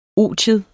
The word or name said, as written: otium